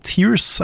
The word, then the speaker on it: tears: Det er samme som bear og beer. – Ja. Så det som er riktig, er å si tears of the kingdom. Ja. Med i og ikke e.